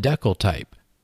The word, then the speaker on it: decltype: I'm not sure if it has an agreed-on pronunciation, so I call it decal type.